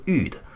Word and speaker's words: 曰: 曰于未有世家，这个曰字啊，在这里是一个发语词，也有读作欲的。